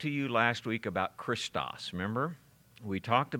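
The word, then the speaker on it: Christos (Χριστός): So Christ begins with CH to us, but it begins with an X in that language, and so we pronounce it Christos.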